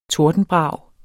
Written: TORDENBRAG

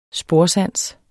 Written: sporsans

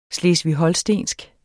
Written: HOLSTENSK

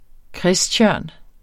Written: kristtjørn

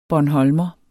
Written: bornholmer

